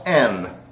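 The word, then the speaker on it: en (ἐν): It's pronounced en. It's equivalent to in, so it's easy to remember.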